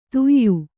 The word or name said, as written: ಧೇಯ